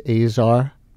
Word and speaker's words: Azar: And I guess I was mispronouncing his name, A-Z-A-R, it's Azar. – Azar, there's been some debate about it, but my understanding from asking around and listening to him is that it's Azar, yes.